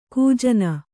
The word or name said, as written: ಗಜನಿ